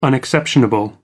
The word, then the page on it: unexceptionable: American English